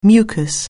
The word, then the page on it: mucus: anglais